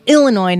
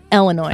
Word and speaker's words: Illinois: Because I say Illinois. – It's Illinois, not Illinois. – I say Illinois.